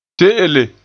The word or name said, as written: te'ele